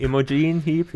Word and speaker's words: Imogen Heap: Im, im Imogen Heap? – Imogen Heap, ja, ich glaube.